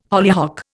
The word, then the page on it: hollyhock: Indian